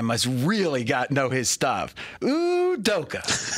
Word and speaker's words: Udoka: My favorite new coach, or at least I like to say his name, for the first time ever, Udoka.